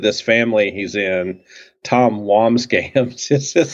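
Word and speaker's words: Wambsgans: Tom Wamsgams? – That's it.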